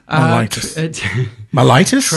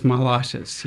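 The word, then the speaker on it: myelitis: And correct me if I'm wrong, but I hope this is how you pronounce it. It's transverse mitis. – Mellitus? – Transverse mellitus, yes.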